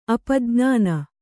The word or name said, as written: ಅಪಜ್ಞಾನ